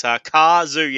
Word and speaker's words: Cazoo: I hope I'm saying that properly, but it's Cazoo. Yeah, so that makes sense. Car and Zoo.